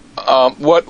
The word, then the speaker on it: arpeggios: How do you say that word? You've heard it say it. – Arpeggios. What is it again? – Arpeggios. I've heard this word before, and I'm not saying it right.